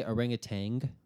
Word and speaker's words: orangutan: Okay, and does everyone else say orangutan? With the G at the end? – I try not to, but I inevitably do. – Isn't that how everyone pronounces it? – Orangutan. – Orangutan. – Orangutan. Right? – That's how it looks, but that's how no one says it.